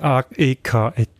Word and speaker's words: AEK: Die Europa League Quali, das FC St. Gallen stoppt vom Spiel gegen AEK Athen. Mm. Richtig ausgesprochen. – Richtig AEK ausgesprochen.